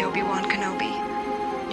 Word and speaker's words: Obi-Wan Kenobi: Help me, Obi-Wan Kenobi.